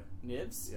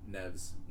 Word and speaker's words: Neves: Nibs? – Yeah, Neves. – Neves? – Yeah. Not Nibs.